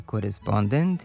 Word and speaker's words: korespondent: Delinkvent, kontingent i korespondent imaju kratko uzlazni akcenat na pretposlednjem slogu, jer prema pravilima naše akcentuacije poslednji slog ne može biti akcentovan.